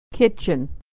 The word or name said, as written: kitchen